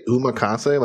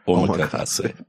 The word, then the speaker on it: omakase: Did you say umakase, like Uma Thurman? It's omakase.